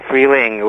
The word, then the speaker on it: Freeling: This song, Freeling. Did I pronounce it correctly? – Yeah, Freeling would be springtime.